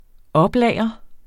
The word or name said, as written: oplagre